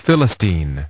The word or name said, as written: Philistine